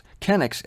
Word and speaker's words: chenix: It's spelled C-H-E-N-I-X, but it's pronounced kenix, and it's 1.5 pints by our standards.